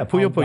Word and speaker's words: Puyo Puyo: Puyo Puyo, am I saying that right? – Yeah, Puyo Puyo.